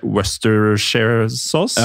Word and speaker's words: Worcestershire: Worcestershire-saus, liksom. Ja, som tydeligvis er Westershire-sauce.